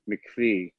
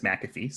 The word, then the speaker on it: McAfee: Well, so it's named after John McAfee, and I believe I've heard him pronounce him, his na- own name as McAfee.